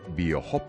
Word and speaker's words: BioHope: Lo he dicho bien, BioHop, se escribe BioHope con H. – Sí, efectivamente. Bueno, en realidad es si lo dijeras en inglés puro sería BioHop. – Sí. – Y en español, BioHop.